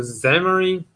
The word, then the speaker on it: Xamarin: Acho que se pronuncia Xamarin, acho que o X tem som de Z.